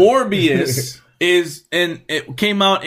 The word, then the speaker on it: Morbius: Morbius. – Morbius.